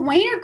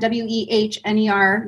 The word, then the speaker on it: Wehner: No one usually knows how to pronounce it. I when I met my husband, I was 18. I thought it was Weiner because it looks like that.